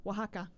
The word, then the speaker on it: Oaxaca: Oaxaca. – Oaxaca.